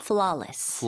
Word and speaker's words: flawless: 발음할 때 그러면 flawless라고 해야 될까요? – 아, W가 있어서 그렇게 생각하실 수도 있겠지만, 발음은 flaw. – flaw, flawless. – flawless.